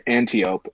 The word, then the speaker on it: Antiope: But either way, still works the same because the next one is Antelope. How do you say it? Antiope? Okay. Antiope, she's a captain, 40 points.